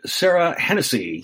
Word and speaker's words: Serah Henesey: I have with me the lovely and talented Serah Henesey.